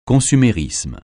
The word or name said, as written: consumérisme